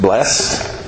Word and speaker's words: blessed: Other translations have the word B-L-E-S-S-E-D. The word could be pronounced blessed or blessed.